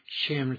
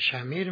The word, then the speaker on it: شمر: غالبا میگن که همین اسم شمر بوده، لکن ما ایرانی ها که عجم هستیم شمر تلفظ میکنیم. معمولا عرب ها شمیر مینوشن و شمیر میخونن.